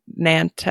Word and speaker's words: Nantes: at the monastery in Nantes. – Notre Dame. Yep. Okay. – But not the one in Paris. – Correct. – In, how do you say that? N-A-N-T-E-S. Nantes. Nantes. Sure.